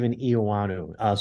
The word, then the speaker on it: Eoannou: My name is Steve Eoannou.